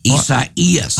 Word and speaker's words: Isaias: The emphasis is on the second E. Isaias.